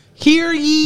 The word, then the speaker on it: hear ye: Here he. Am I saying this right? – Here he. It's ye. – Here ye. – Like think, hear think ye.